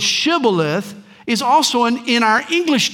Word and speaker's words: Shibboleth: We're told, and he, that is the disguised Ephraimite who was fleeing the battle, he would say Sibboleth, for he could not pronounce it right. In other words, the native Ephraimites couldn't say the shush sound.